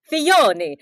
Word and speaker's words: Filoni: Well, Filoni seems like a guy that still has a lot of his Are we going the Italian pronunciation? – Is that it? I don't know. – Filoni. – Filoni. How do I say it? – Filoni. – Filoni. – You got the very Italian there.